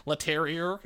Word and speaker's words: Leterrier: I'm trying to get the French pronunciation. Leterrier, if you're a born American.